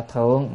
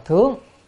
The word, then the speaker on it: thướng: Dĩ hoa cái thượng, cái chữ này không phải đọc là thượng mà đọc là thướng. Tại sao đọc thướng mà không đọc thượng?